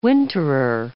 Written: winterer

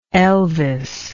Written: elvis

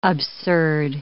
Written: absurd

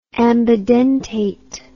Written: ambidentate

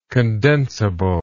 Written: condensable

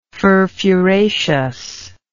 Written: furfuraceously

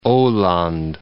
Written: Aland

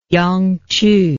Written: yangqu

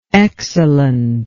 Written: excellently

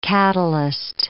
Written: catalyst

